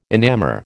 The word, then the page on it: enamour: American English